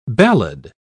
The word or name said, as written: ballad